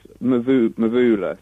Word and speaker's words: Mvula: Is it Mvula? – Well, it's Mvula, so Mvula.